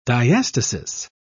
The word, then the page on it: diastasis: British English